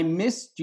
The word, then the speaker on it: missed: I missed you, missed, with a T, even though it's E-D, it sounds like a T.